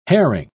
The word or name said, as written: herring